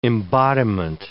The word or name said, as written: embodiment